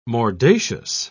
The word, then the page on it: mordacious: British English